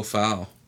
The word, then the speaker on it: Fau: Our kids had a baseball coach, and his name was Foul, and that's how you spell it. F-A-U, Foul, Coach Foul.